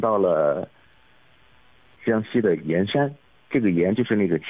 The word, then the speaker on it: 铅山: 对对对。这是古汉语里的一个读音，古汉语里的一个读音。其实这个字只有在盐山这个地名的时候才读盐，其实是当地的这个古汉语，当地的这个方言啊，一直读盐。